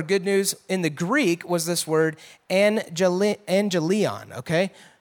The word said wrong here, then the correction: euangelion: Angelion or euangelion, sorry, I'm saying it wrong. Euangelion, actually means good news.